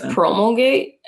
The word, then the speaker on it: promulgate: That I think it's promulgate. I say it like Colgate, promulgate.